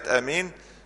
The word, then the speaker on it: آمين: فهي عامة المقصود ان كلمة آمين بالايه؟ بالمد. يعني قبل المد يعني اطول الالف اللي بعد الهمزه، لان امين في عند الف بعد بنطق همزه وبعدها ايه؟ الف. لكن بالقصر بنطق همزه بس امين. فالنطق الصحيح في كلمه امين بالايه؟ بالمد.